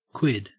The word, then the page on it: quid: Australian English